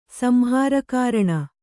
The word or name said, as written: ಸಂಹಾರಕಾರಣ